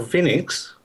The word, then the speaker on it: Vinix: Well, Vinix, which rhymes with Linux, is a version of Linux that Tony Sayers, the founder of Vinix, started to basically hack up with out-of-the-box accessibility enabled.